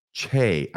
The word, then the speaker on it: Choi: Now we go, go to Elder Yong Hwan Che, I think is how it's pronounced. I think it's spelled C H O I, but I, they pronounced it Che of the 70.